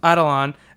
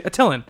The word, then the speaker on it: Attilan: Adelon. – As he spelled it, he used to say Attilan, until I heard you pronounce it Adelan.